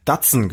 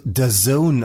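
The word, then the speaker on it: DAZN: So, und jetzt, ich hab's tatsächlich, weil ich dachte, Junge, du hast keine Ahnung von Fußball, aber du darfst dich nicht blamieren, du musst mal recherchieren, wie das gesprochen wird. Das wird Dazen ausgesprochen.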